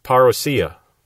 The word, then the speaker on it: parousia: Now, some people have Englished that and made it parousia, but the Greek word would be pronounced parousia, and that's how I'm going to be pronouncing it.